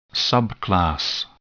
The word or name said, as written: subclass